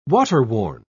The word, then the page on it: waterworn: British English